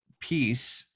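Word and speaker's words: Piesse: I think Peace is the way you say her name is reprising her role as Aunt Beru.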